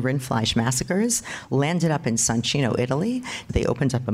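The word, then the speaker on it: Soncino: I used to pronounce this Sancino. I gave a lecture to a group of Italian Jews. One of them came over after. He said, "Very nice lecture, but the entire time you keep saying Sancino. It's Sancino, Sancino, Sancino." So he traumatized me, and now I always pronounce it correctly.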